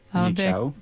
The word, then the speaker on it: Manu Chao: Manu Chao.